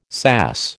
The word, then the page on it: SAS: American English